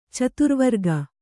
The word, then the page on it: ಚಾತುರ್ವರ್ಗ: cāturvarga